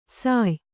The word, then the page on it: ಸಾಯ್: sāy